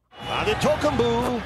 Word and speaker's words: Antetokounmpo: And here's how he pronounced Antetokounmpo. – Antetokounmpo for three. – Antetokounmpo for three. He nailed for three. He nailed that.